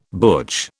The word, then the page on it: butch: British English